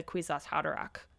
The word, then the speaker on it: Kwisatz Haderach: Okay, and then his third, and I think what the book is trying to say, his most important birthright is as the Kwisatz Haderach. Somebody said it rhymes with knick-knack paddywhack, and that will help you to remember.